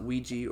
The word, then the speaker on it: Ouija: Yeah, I mean, people pronounce it Ouija or Ouija, but it's oui, ja for a French, yes, yes. French yes and German yes.